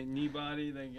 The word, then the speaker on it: Kneebody: Oh, okay, Kneebody.